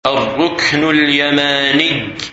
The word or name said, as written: Ar Rukn Al Yamani